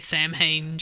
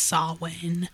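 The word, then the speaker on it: Samhain: And I only know the Saul Wayne Saul Wayne. It's so hard to say because they talked about it on the last drive-in. But Joe Bob likes to say Sam Hain just to piss everybody off.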